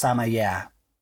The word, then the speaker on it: Sameyakh: I think it's just pronounced Samaya. So it's easier than it looks.